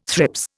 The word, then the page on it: thrips: Indian